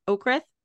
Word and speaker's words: Oakrith: I'm very excited to talk to you about your Five Crowns of Oakrith. That's how you say it, right? Mm-hmm. Oakrith series.